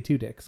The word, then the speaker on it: Tudyk: And I did say Tudyk because that's his name. – That's how you say it.